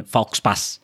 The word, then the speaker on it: faux pas: So it's spelled as F-A-U-X-P-A-S. Well, if it were a Filipino word, it would have been faux pas. – Yes. – Faux pas. – Yeah. – But pronounced as how faux pas, because, you know, so it's French. They always spell it differently and weirdly.